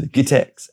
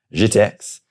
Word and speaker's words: GITEX: Because happening almost at the same time, at almost the same venue, was, now, I've heard it pronounced as GITEX and JITEX.